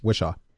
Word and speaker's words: Whishaw: Wishlaw. I think. Wishlaw. Oh, no, Wishaw. Okay. Yeah, I think you had it right. Wishaw.